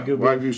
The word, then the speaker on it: Wagyu: Yeah, Wagyu steak.